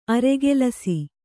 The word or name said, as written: ಆರುಕೆಲಸ